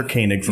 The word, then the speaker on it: Koenig: It's Matt Groening and Walter Koenig from Star Trek. – Yes, exactly. – And until I tell them that there's actual evidence of people out there who pronounce O-E as A, they don't believe me.